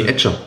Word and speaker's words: Etcher: Fetcher. – Ja. Nee, Etcher. Etcher. Etcher. Etcher Entschuldigung, genau. Etcher, nochmal ordentlich ausgesprochen.